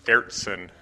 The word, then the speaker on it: Ayrton: Pronounce the name of the hotel again. – It's Ayrton. My Dutch might be a little off, but that's the basis of it.